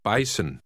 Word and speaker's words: Bison: Remember the letter in the middle is pronounced like a double S. Now read Word 4. – Bison.